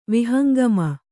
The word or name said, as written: ವಿಹಂಗಮ